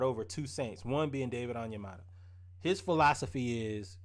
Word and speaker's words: Onyemata: David Onyemata.